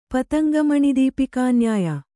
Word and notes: ಪತಂಗಮಣಿದೀಪಿಕಾನ್ಯಾಯ: pataŋga maṇi dīpikā nyāya; Kannada

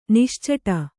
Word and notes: ನಿಶ್ಚಟ: niścaṭa

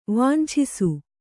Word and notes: ವಂಚಿಸು: vancisu

